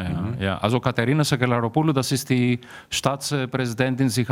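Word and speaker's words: Sakellaropoulou: Also seit zwei Jahren ist ja eine Frau Staatspräsidentin, Katharina Sack Sakelaropoulou, habe ich mhm. das richtig gesagt? Sakelaropoulou. Sakelaropoulou, ja.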